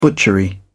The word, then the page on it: butchery: British English